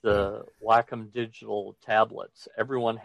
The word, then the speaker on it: Wacom: So I think he said Wacom, because it's like what you do with your dog, you Wacom.